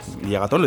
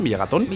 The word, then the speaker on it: Villagatón: Bueno, vicepresidenta, eh, vicepresidenta de la Diputación de León y además alcaldesa de Villagotón. Villagatón, lo he dicho bien, Villagatón.